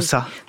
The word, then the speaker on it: Xhosa: Kosa. – Kosa, ça s'écrit X-H-O-S-A.